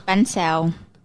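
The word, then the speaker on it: Bánh xèo: Ban Zhao. – Yeah. So it's like I said. And then what I think that I'm ordering is a seafood crepe. Is it a seafood Ban Zhao?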